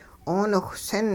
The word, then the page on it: 'ooniwhsin: hupa